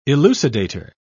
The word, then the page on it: elucidator: British English